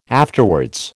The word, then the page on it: afterwards: American English